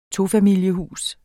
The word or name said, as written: tofamilieshus